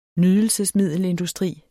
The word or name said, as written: nydelse